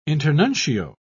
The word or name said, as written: internuncio